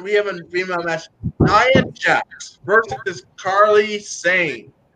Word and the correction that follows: Kairi Sane: It's pronounced Kyrie Sane.